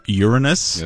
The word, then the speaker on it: Uranus: Andrew, believe it's pronounced Uranus.